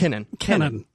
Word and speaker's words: Kenon: Kenon. Yeah, like Ken.